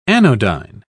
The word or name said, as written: anodyne